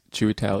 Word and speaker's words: Chiwetel: I think it's Chiwetel Ejiofor.